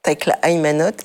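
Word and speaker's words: Tekle Haymanot: Takla, Emano et Evostateos. – Tekla Aimanot et Eostateos.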